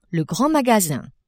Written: grand magasin

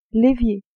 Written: évier

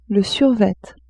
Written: survêt

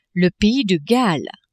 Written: pays de Galles

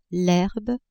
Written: herbe

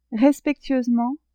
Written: respectueusement

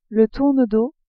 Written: tournedos

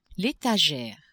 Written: etagere

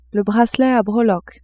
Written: bracelet a breloques

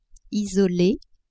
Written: isole

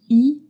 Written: i circonflexe

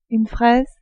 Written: fraise

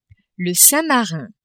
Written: Saint-Marin

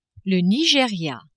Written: Nigeria

